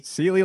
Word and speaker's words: Sealy: Sealy, like the mattress, but Sealy, if you see the spill, like, mine's worse.